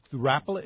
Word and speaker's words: Rapalas: No, that's Rapalas is correct.